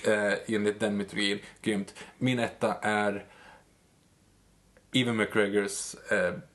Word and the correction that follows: Ewan: Ewan, man uttalar hans namn.